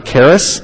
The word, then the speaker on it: charis: The root word for grace is charis in the Greek, where charity comes from. We pronounce it with a hard C-H.